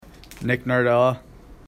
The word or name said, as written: Nardella